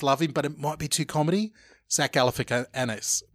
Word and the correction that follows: Galifianakis: Well, I've said that wrong. Zach Galifianakis. – Galifianakis.